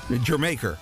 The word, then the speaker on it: Jamaica: Yes, that's how it's pronounced. Jamaica. Just like Jamaica, except hang a few R's on the end of the syllables. Jamaica.